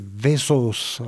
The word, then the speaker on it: Vesaas: Un Norvégien, on n'a pas parlé encore trop d'auteurs scandinaves, Tarjei Vesaas. Alors ça s'écrit avec deux A, j'ai appris il n'y a pas longtemps que les deux A en norvégien ça se prononce O. Voilà.